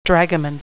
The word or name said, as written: dragoman